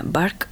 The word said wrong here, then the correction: Burke: Burke. Burke. Solomon Burke.